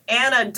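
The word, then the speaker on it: Ana: Yeah, I've got the Twitter and the Instagram, and you can find me at Anna Dumbass Kid.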